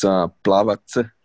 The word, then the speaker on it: Plavac: Damn, it's Plavac. So C is like a T, TZ.